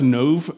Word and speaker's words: Nob: Nob, N-O-B. It's probably pronounced Nov, just like a Hebrew-sounding pronunciation, but I like the idea,